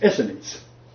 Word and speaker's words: Essenes: Yes, I'm talking about the Essenes, who should be pronounced Essenes. It's like Pharisees, Sadducees, and others. It's Essenes, not Essenes.